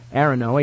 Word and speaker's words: Aronow: Oh, by the way, his name is spelled A-R-O-N-O-W, and I've never heard it pronounced. Is that... – I would say Arono. – Arono, think so, yes. A-R-O-N-O-W.